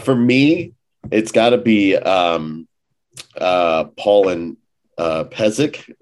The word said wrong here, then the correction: Pasek: Is that how you say it? – Pasek and Paul.